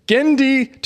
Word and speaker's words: Genndy: I was struggling on how to say it, but I'm glad you said Genndy. – It. Well, how do you say it? – Genndy. – Oh, it's Genndy.